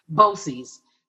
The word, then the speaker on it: BOCES: So first of all, clarify for me and also the viewers, is it pronounced BOCES? – BOCES. Yes, BOCES is an organization,